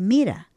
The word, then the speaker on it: mira: Mirá, y ponen el acento en las palabras donde a mí siempre yo reclamaba, pero al final es la idiosincrasia de ellos, porque decía, no, es que no es así gramaticalmente, no dice mirá, es mira.